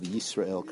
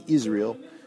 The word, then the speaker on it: Yisrael: You should make sure to pronounce the Yud of Yisrael correctly. Shelo Tibola V'Shelo Teira'e Aleph. Shema Yisrael. Right? You don't want to sound like Israel. Like in English, we say Israel.